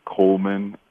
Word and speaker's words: Coleman: Oh, they can follow me at just my name, Vernal Coleman.